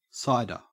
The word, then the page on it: cider: Australian English